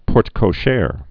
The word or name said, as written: porte-cochere